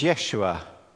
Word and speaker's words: Yeshua: The word, of course, is in Hebrew is Yeshua. In our usual English pronunciation, of course, we pronounce it, and it's the name Joshua. Or in Greek, of course, it's the name Jesus.